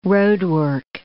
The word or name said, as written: roadwork